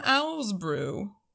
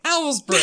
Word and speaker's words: Owl's Brew: Speaking of wine, in this case beer, on tonight's episode menu, we have the Blondie from Owl's Brew. I said it right. – You said it right. – Owl's Brew.